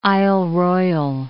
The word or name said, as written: Isle Royale